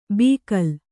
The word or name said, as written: ಬೀಕಲ್